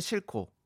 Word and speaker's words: 싣고: 아, 쓸 때는 싣고 그리고 발음할 때는 싣고 아, 그렇습니다.